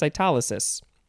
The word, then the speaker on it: cytolysis: A substance as an antibody that lyses bacteria, producing cytolysis. That will be our next word. So it's a substance producing cytolysis. How do you say this? Cytolysis. Yeah, cytolysis. And the example is just an antibody that lyses bacteria. L-Y-S-E-S.